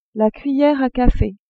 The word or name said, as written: cuillere a cafe